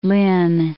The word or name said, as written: Lynn